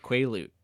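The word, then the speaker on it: Quileute: Sam Yuli, a member of the werewolf, Quaalute tribe. – Quaalute. Not Quaalude. – I keep doing I made that joke too many times. Now that's how I actually say it. Quaalute. Quaalute. Like quilt. Quaalute.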